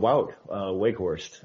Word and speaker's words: Wout Weghorst: And Wout Weghorst, if that's how you actually pronounce it.